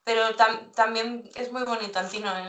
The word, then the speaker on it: Antinoë: ¿Lo pronuncio bien, Antínoe, o cómo se...? – Bueno, yo suelo decir Antínoe porque en realidad viene de... Es el nombre de una ciudad en femenino, en griego.